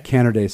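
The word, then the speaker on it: Canerday: Natalie Carnaday, am I saying that right? – Carnaday, sorry.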